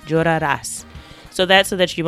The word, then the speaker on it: llorarás: So llorarás, it's very important to have that stress on the last syllable.